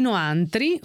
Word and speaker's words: Noantri: La voce è L'Italia dei Noantri, ho detto bene? – Sì.